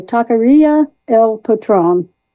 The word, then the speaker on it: Taqueria El Patron: We went down to Georgetown, and we were very happy to, I'm going to try to pronounce it correctly, Taqueria El Patron.